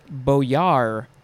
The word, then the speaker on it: boyar: Next is boyar, or boyard. You could spell it with a D at the end or not, but I don't think it's ever pronounced with a D.